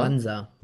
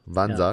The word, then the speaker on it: Mwanza: jetzt muss ich die Stadt richtig aussprechen, äh, Mwanza. – Mwanza. – Wansa, ja. genau. Und ähm,